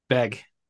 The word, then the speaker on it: Begg: Begg.